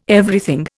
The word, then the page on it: Everything: Indian